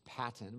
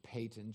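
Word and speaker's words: Paton: That's P-A-T-O-N. Some would pronounce it as Patton, but it's actually Payton.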